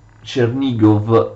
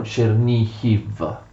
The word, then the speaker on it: Chernihiv: Il terzo figlio eh, Svetoslav riceve Chernigov, che eh, gli ucraini scrivono e pronunciano Chernihiv.